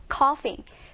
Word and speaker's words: coughing: coughing. coughing은 기침이라는 뜻이고요. 약간 스펠링하고, 그 다음에 발음하고 좀 다르죠. gh가 같이 이렇게 묶여서요. 거의 f 발음이 나기 때문에 coughing, coughing으로 발음하시면 됩니다.